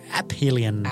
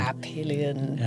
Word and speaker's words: aphelion: And it's tempting to put the A-P-H and pronounce it aphelion. – Yes. – Aphelion. – Aphelion. – Why is that? Why do we pronounce the helium by itself? – It's all Greek. – It's all Greek to you. – It's all Greek to me. Because helios means sun.